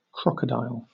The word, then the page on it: crocodile: British English